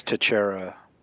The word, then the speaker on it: Teixeira: That's Tichera, phonetically, not to be confused with Tichera. who you don't want to make that mistake.